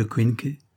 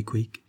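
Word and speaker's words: Quincke: Sur les raisons de son absence, un déménagement long et pénible avec tous ses cartons à faire et défaire, un œdème de Quinque, elle avait prononcé Quick, mal soigné.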